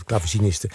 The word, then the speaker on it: clavecinisten: het tweede deel uit het vijfde Brandenburgsconcert van Bach met Les Arts Florissants onder leiding van Emmanuel Rechica-Certa en de clavecinisten, zeg ik dat goed? Dat zeg ik goed, clavecinisten, was Béatrice Martin.